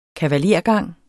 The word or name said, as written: kavalergang